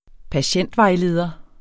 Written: patientvejleder

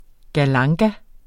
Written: galanga

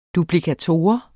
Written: duplikator

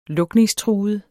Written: lukningstruet

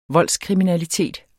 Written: voldskriminalitet

